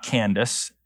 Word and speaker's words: Candace: Now, by the way, the word Candace, not Candace, not Candace, it's pronounced Candace here, is a title.